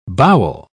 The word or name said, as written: bowel